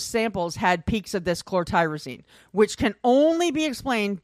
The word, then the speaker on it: chlorotyrosine: Chlorotyrosine. It's chlorotyrosine.